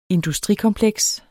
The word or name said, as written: industrikompleks